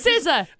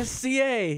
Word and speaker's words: SZA: SCA. Yeah, was it SZA? Which Jen still calls SCA because she refuses to pronounce her name.